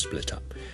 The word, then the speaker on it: split up: Okay, we should split up。咁佢听咗好多次呢，佢觉得啊，点解个发音好似同我平时嘅发音好唔同呢？我应该点样去听落去会好啲呢？如果係一个英国嘅发音咁，其实有两件事要留意嘅。第一件事要留意嘅呢， split up 咧个 t 可以 link 埋落去 split up split up，但系如果你系好英国 local 唔系好所谓 receive pronunciation 嘅话咧，你咧可以就咁食咗个 tm 就会 split up split up。